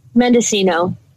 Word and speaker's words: Mendocino: Is it Mendocino? – Mendocino.